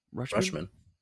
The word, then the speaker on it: Rutschman: So I like Rutschman. Am I saying that right? Adley? – Yeah. Rutschman.